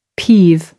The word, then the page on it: peeve: American English